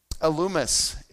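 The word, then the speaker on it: Elymas: Elumas is actually how you pronounce that name.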